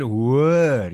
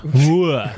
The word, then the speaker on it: Hoër: Oh, sorry, my pronunciation is terrible. – Say it weer. – Hoërskool. – Nee, man, Hoër. Jy moet het. – Oh, that's Hoër.